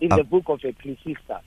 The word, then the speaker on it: Ecclesiastes: I, I pronounce it as Ecclesiastes. Okay. Ecclesiastes. Okay. Yes. yeah, there she gave you a, round, a, right, a right pronunciation.